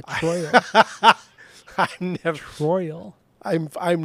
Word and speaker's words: Troyal: Oh, dude, he was born Troyal Garth Brooks.